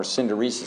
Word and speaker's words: synderesis: And this is related to what Aquinas discusses elsewhere in the Summa Theologiae, the power of synderesis, or synderesis, it's sometimes pronounced.